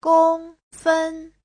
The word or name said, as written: gong1fen1